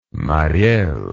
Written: Mariel

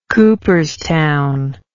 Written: cooperstown

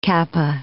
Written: kappa

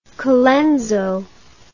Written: Colenso

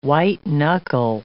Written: white-knuckle

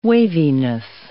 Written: waviness

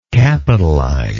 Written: capitalizer

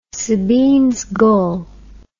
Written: Sabine's gull

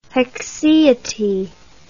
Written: haecceity

